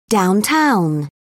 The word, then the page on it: downtown: British English